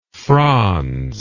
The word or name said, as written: frons